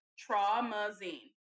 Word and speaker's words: Traumazine: So she posted just this blank, or just the definition of traumazine. That's how you pronounce it.